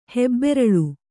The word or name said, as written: ಹೆಬ್ಬೆರಳು